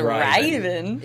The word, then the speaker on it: Raiven: It's spelled Raiven. It's called The Riven Destiny. – That explains why we couldn't tell if he said Raven or Riven. – Yeah, because it's Riven.